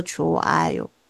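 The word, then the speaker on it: 出 爱 哟 (좋아요): 下面这个" 呵" 啊，在这里也不发音，不要理它，当做没有它的存在。但是要写出来哦，要写出来，但是不发音，它念作" 出爱哟” 哦，" 出爱哟”，它是好的意思。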